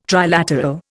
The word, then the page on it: trilateral: Indian